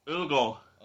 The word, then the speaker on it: Ødegaard: Odegaard.